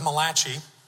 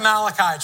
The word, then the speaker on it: Malachi: I want you to go with me to the book of Malachi. No, that's not how it's pronounced. Book of Malachi, chapter 2.